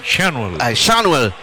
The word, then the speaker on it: Schanuel: Schanuel